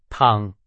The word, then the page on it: 躺: Chinese